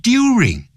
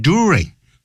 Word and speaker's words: during: He came of age during, 他不会念成 during, 他念 during。